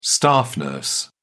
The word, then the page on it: staff nurse: British English